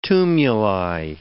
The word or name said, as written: tumuli